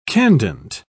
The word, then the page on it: candent: British English